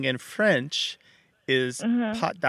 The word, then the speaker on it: pâte: So it used to be spelled P-A-S-T, but now it's spelled P-A-T-E. But you translated the word P-A-T-T-E, which means paw or leg of an animal. – Yes. – So you translated, so you, it, they sound almost the same.